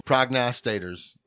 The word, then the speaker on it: prognosticators: I mean, prostators. I mean, prognosticators.